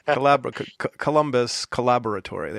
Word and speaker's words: Collaboratory: You want to say collaboratory, but it's like collaboratory. Is it the British pronunciation or the English? – Yeah. Yeah, that's the UK pronunciation. – Columbus Collaboratory.